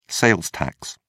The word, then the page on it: sales tax: British English